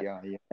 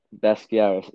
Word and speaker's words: Basquiat: This one is honoring, I'm going to butcher this name, but Jean-Michel Basquiat or something like that. – Basquiat, And it's Basquiat, yeah.